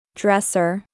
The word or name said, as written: Dresser